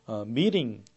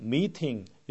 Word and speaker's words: meeting: 그리고 meeting 이렇게 읽는 것이 미국식이라면 meeting 이렇게 읽는 것이 또 영국식이 되죠.